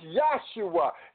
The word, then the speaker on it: Yahshua: His name is Yahshua. There is no J in the Hebrew language.